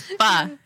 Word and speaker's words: pho: I know, because everybody's like, pho. No, girl. Forget about that. It's pho.